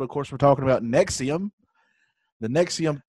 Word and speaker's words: NXIVM: N-X-I-V-M. And it's Nexium.